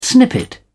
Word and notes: snippet: British English

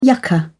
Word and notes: yucca: British English

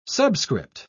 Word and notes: subscript: British English

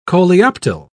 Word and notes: coleoptile: British English

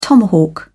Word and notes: tomahawk: British English

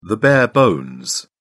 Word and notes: bare bones: British English

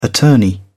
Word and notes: attorney: British English

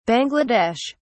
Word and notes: Bangla Desh: British English

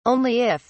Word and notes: only if: British English

